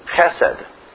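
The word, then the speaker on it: chesed (חסד): It is chesed. Often we spell it H-E-S-E-D.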